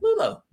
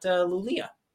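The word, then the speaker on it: Luleå: Actually, L-U-L-E-A, as we learned from our Swedish friends, pronounced again, Lulo, not Lulia.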